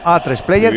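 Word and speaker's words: A3Player: en A3 Player Premium, lo he dicho bien, ¿no? A3 Player sí, Premium. – Perfecto.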